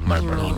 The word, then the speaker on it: Marlboro: Oye, sí, es que una R es que no suena. No se pronuncia. – ¿No se pronuncia? – Marlboro. Nadie dice Marlboro.